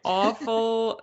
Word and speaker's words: Offal: Okay, Offal.